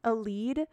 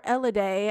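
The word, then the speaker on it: Elide: And Alma's like, Aleed... or Eliday, as I should say.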